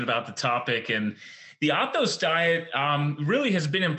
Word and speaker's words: Athos: He is the founder of the Athos Diet. Did I pronounce that right? – That's right. Perfect.